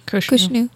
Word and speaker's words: Khonshu: Maybe now it's Iraqi Freedom, but he falls into a temple that was hidden, the temple for Khonshu. I don't know how you say it. However you say the Egyptian god. Khonshu? – Khonshu.